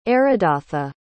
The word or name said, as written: aridatha